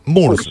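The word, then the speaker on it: Moores: Te consulto, mira, como el alumno le pregunta al maestro, el alumno torpe le pregunta al maestro, ¿cómo se debe pronunciar tu apellido, Ezequiel? – Murs, ¿no es cierto? Bueno, bien. Porque yo lo he escuchado Murs, lo he escuchado que a veces te dicen Mores, lo castellanizan, y es descendiente irlandés, ¿no es cierto?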